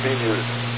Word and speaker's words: BMEWS: That was the early system, and the later system, BMUSE, Ballistic Missile Early Warning System, BMEWS, pronounced BMUSE.